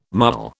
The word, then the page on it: Mughal: British English